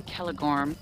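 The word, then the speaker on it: Celegorm: Like C is always hard C, like Curdan or Kelegorm, and G's are always a hard G.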